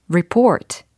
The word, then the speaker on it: report: R-E-P-O-R-T라는 철자입니다. 발음을 한번 해보세요. 오, 여러 가지 발음이 들리고 있습니다. 레포트, 리포트. 네. 근데요, 정확한 발음은 report, report, report, report.